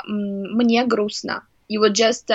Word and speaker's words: грустно: So, and then the next thing they hear is грустно, right? – Yeah.